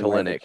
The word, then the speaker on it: Kelenic: Kelenic.